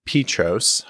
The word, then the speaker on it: Peatross: She goes by the name of Dr. Jess Petros.